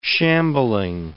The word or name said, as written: shambling